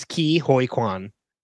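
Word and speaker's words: Ke Huy Quan: Ki-Hoi, it's Ki-Hoi Kwan.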